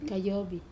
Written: kayobi

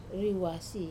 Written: ruase

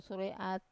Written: sura e at

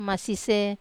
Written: masise

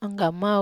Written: anggamau

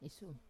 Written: isum

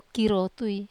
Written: karotui

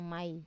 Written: mai

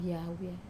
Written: awia

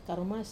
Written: karumas

